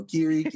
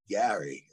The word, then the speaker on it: Gary: Being in Minnesota for so many years now and hearing my name mispronounced, you know, Gary, it's so nice to hear Gary. It's like that takes you back to New York.